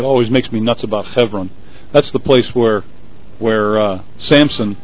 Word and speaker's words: Hebron: Somebody asked, what's the correct pronunciation of Hebron? It would be, in Hebrew, Hebron.